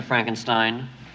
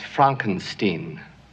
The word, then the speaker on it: Frankenstein: I have one question, Dr. Frankenstein. – That's Frankenstein. – I beg your pardon? – My name. It's pronounced Frankenstein.